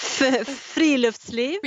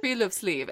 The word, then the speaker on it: friluftsliv: help me pronounce it sleeve Is that Friluftsliv? – Friluftsliv.